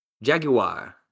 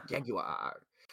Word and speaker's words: Jaguar: Jaguar, yeah. – Jaguar.